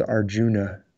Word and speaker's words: Arjuna: If you're familiar with the Bhagavad Gita, we have the picture of Arjun. It's spelled Arjuna, but it's usually pronounced Arjun, who is very capable.